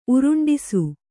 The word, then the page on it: ಉರುಂಡಿಸು: Kannada